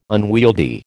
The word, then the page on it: unwieldy: American English